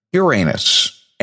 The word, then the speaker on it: Uranus: And I can still remember hearing Tom Brokaw of NBC News pronouncing it as Uranus and thinking, it's not Uranus, it's Uranus. And then, of course, after time, understood the reason why he wanted to go to an alternate pronunciation. There is also one other alternate pronunciation, and that is Uranus. However, again, I'm going to head back to the astronomically approved pronunciation, Uranus, and we will go from there.